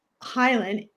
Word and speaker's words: Hylen: And my last name is Hylen, H-Y-L-E-N, so it's help you leverage everything now.